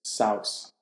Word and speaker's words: Seuss: We've been pronouncing Dr. Seuss's name wrong this entire time. – Is it Seuss? – It's Saus.